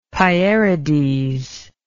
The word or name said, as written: Pierides